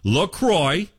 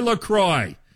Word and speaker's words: LaCroix: Officially, it's pronounced LaCroix, L-A space, C-R-O-I-X. So it rhymes with enjoy. Enjoy LaCroix. It's not LaCroix or LaCroix, like the French might say.